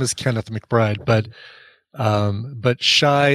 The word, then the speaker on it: Chi: Well, no, it's pronounced Shy, by the way.